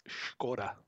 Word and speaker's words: Skoda: First, we're going to pronounce Skoda correctly. So it's Skoda.